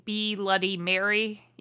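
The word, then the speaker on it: B'loody Mary: Ebony's best friend is named B. Luddy Mary. Yes, I'm going to always say it like that because it's a B apostrophe L-O-O-D-Y.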